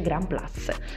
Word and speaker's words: Grand Place: Sì, sì, sì, sì, si pronuncia così. mi hanno già sgridato una volta, sono sicura che sia così. In francese si dice Grand Place.